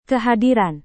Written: kehadiran

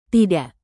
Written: tidak

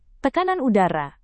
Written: tekanan udara

